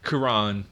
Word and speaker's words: Cuarón: Cuarón? – I wanted to say Rivera. – No. – Cuarón.